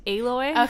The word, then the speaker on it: Aloy: It's pronounced Aloy. – Okay. – It's Aloy.